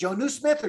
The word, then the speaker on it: Jonnu: Jonnu, is it Jonnu Smith or John? – Jonnu Smith.